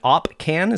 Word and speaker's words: Opcan: Is it, how do you say it? Is it opcan? Is that how you say it? – That's right.